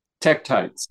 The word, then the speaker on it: tektites: Are they called titicites? Did I say that correctly? – Tektites. – Tektites. Thank you.